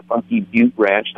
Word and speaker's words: Funky Butte: The temptation to say funky butt, of course, is obvious, but I mean, it does have the E on the end, which would indicate that you have a long vowel sound.